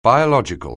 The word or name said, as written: biological